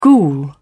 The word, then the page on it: ghoul: British English